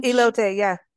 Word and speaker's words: Elote: And I don't know how to pronounce it. Elote. Mm-hmm. It's a Mexican corn dish. – Elote, yeah.